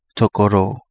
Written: Togo Loo

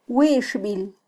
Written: wixbil

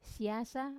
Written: siasa

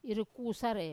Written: irukusare

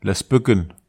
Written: las peken

